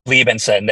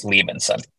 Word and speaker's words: Liebensohn: It's Liebenson. The H is silent, so just Liebenson.